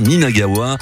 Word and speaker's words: Ninagawa: Alors oui, je le dis bien, Ninagawa.